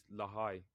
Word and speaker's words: Lahai: The name the name of it is Lahai, or it's spelled L A H A I.